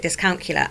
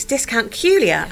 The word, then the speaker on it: dyscalculia: So she taught me that discount, because I used to call it dyscalculia, and it's dyscalculia, and it rhymes with Julia.